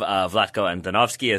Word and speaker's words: Vlatko Andonovski: Vlatko Andonovski as the head coach of the U.S.